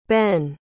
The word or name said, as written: behn